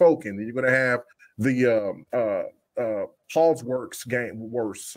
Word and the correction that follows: Hogwarts: Uh, Hogwarts.